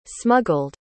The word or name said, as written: smuggled